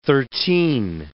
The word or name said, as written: thirteen